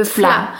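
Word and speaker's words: vla: Ok, et ça, ça s'appelle, tu dis, le, le fla. – fla. Et ça s'écrit avec un V. Oui. Par contre, ok, d'accord. Donc, est-ce que les, les V se prononcent F en néerlandais.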